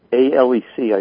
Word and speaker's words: Alec: But some people do pronounce it as if it had an X on the end. Right. In very careful pronunciation, it would be Alec.